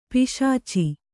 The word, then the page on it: ಪಿಶಾಚ: kannada